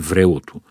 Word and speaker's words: врелото: Така че правилното му ударение е врелото.